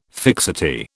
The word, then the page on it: fixity: British English